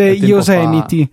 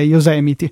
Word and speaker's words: Yosemite: Sì, si legge Yosemite. Fa... So che quando sì. ci sono stato là in zona avevo proprio indagato su quale fosse la pronuncia corretta, è Yosemite. – Yosemite, perfetto.